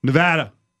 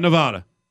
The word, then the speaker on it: Nevada: We hate when people say Nevada. So, which, can we do that at every press conference moving forward? – Yeah. – Just stop them? – Yeah. – Nevada. Not Nevada.